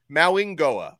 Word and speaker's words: Mauigoa: But at the same time, Blue would not surprise me whatsoever if Maui Goa is, and actually, let me try to pronounce his name correctly. I've been corrected on this twice. Mauingoa, I think is how you say his name oh, correctly.